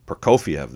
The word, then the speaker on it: Prokofiev: Okay, flipping it over, we've got Prokofiev Boogie, if I'm saying that right. Sergei Prokofiev, Russian composer who composed Peter and the Wolf. – Prokofiev, that makes more sense.